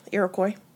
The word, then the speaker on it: Iroquois: Iroquois.